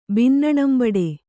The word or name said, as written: ಬಿನ್ನಣಂಬಡೆ